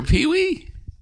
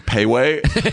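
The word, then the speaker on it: Pee-wee: And I had this whole thing where I wanted to, where I was saying his name wrong, which it was just a choice I made. Yeah. I kept calling him Payway. Hey, Payway.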